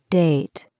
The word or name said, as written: date